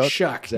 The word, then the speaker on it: Shough: Sure, Shuck. Yes, it's like, it's pronounced like S-H-U-C-K, even though it's spelled S-H-O-U-G-H, which makes no sense.